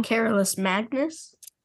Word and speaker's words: Carolus Magnus: Carolus Magnus? Uh, that is how you say it in hmm. Latin.